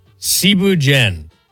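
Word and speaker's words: Thieboudienne: First off, Cebu Jen. It's spelled like Thibu Dien.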